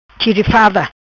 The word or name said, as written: Chirifada